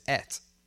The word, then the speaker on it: ate: I look at it, and I says et. Et. I et. Not I ate, I et.